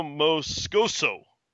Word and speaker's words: Moscoso: Guillermo Moscoso. – Yes. – And that's for those of you spelling phonetically, it's M-O, capital M, lowercase O-H, dash, capital S-C-O-H, dash, lowercase S-O.